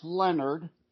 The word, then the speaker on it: Lenard: Yeah, well, you, are pronouncing it right. It's Lenard. – It mm-hmm. looks like Leonard, but it's not French.